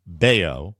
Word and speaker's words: Bello: And minor league righty Jose, it's B-E-L-L-O. It should probably be pronounced Bayo. – Bayo.